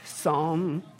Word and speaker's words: Psalm: Psalm chapter 2, for those of you hooked on phonics, how do you say that? Psalm, right? But see, let's just move on.